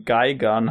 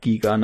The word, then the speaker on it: Gigan: Ich dachte meist Gigan, aber es ergibt Sinn, dass er Geigan heißt. Äh, ja, wäre halt englisch ausgesprochen.